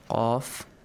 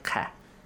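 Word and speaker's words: قاف: قَاف. – قَاف. قَدِس. It has a... – heavy sound. – Heavy sound. – Yes, it's pronounced heavily. قَاف. قَاف. Not ka.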